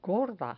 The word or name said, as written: gorda